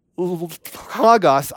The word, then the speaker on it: Logos: This idea of L-O-G-O-S. How you say it? Logos. Thank you.